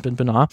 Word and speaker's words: Benoit: Sie sollte so eine Biopic über einen WWE-Wrestler inszenieren, über Chris Benoit heißt der, oder Benoit, glaube ich, wird das ja ausgesprochen. Chris Benoit.